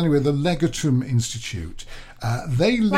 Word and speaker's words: Legatum: Almost. That's legume. This is L-E-G-A-T. More like legal, I think. Legatum. That's how it's spelled. It'd be Latin, wouldn't it? L-E-G.